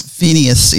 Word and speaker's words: Phineas: Phineas, yes.